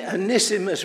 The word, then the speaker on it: Onesimus: And he started a sermon, and he told me that this was from the book of Philemon, and it was about one Simus. Now, if you see Onesimus written, that's how you should pronounce it.